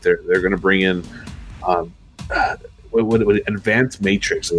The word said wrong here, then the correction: metrics: Matrix or metrics, if you're, but I know you're a big Keanu Reeves fan, so you can pronounce it however you want. – Advanced metrics, excuse me.